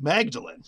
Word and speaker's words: Magdalen: But again, the tricky part of English and in any language really is down the street is a Magdalen Chapel, spelled the same way. and pronounced Magdalene.